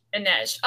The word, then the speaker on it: Inej: How do you say her name? Inej? – Inej.